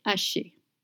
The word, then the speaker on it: haché: You don't pronounce the H in French. It's mute. So you don't say haché, you just say haché.